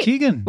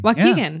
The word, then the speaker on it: Waukegan: Did I say that right? – Waukegan. – Waukegan. Yeah. Excellent.